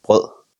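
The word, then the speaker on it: brød: Broad, is that how you say it? – Pol. – With B-R-O with the line through it? – D? Yes, you say pol. – Bro. Bro. – Bro. You don't say the D.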